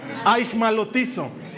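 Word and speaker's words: Aigmalotizo (αἰχμαλωτίζω): Se pronuncia, hecha es difícil, ajmalotizo, A ver, di eso. Aigmalotizo si perseveras en esta iglesia, en poco tiempo hablas griego.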